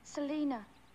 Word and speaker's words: Selina: S-E-L-I-N-A. – Oh, Selena. That's a lovely name. – How'd you say it? – Selena. – Selena. It sure does sound better.